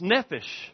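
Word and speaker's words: nephesh (נֶפֶשׁ): In Hebrew, it's pronounced nephesh.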